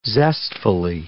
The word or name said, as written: zestfully